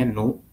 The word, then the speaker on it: GNU: project GNU JNU dibacanya new